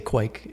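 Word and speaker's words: Kveik: Some people have trouble with the KV. And so you can use a W instead of the V and say Kvik. – Kvik. Rhymes with bike.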